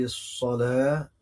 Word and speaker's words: الصلاة: زي الصلاة الواو عليها ألف ما بننطقش الواو بننطق الألف